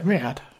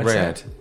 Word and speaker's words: Riyadh: Riyadh. – Oh yeah, go on. – Riyadh.